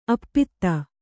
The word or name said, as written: ಆಪಾತ